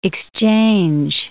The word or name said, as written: exchange